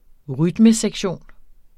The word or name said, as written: rytmesektion